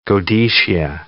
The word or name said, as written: godetia